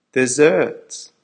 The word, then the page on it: desserts: American English